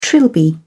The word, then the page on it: trilby: British English